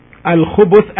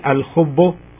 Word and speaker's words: الخبث: Al-Khubuth, as it's pronounced now by me, Al-Khubuth with the Ba, with the letter Ba taking a Dhamma, as you can hear me, Al-Khubuth.